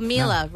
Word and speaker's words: Mila: So Mila rhymes with Sheila. – Okay. – Sheila. Like the Australian big Sheila.